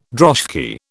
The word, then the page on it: droshky: British English